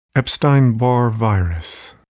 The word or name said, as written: Epstein-Barr virus